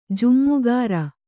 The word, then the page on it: ಜಮ್ಮಗಾರ: jammagāra